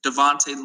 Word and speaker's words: Davonte: So my name is Devontae Logan, and that's spelled D-A-V-O-N-T-E. Last name Logan, L-O-G-A-N, and then Megan as well on LinkedIn.